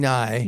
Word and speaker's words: Ngai: Nai. – Nai. – Okay. N-G-A-I. – But you don't pronounce the G.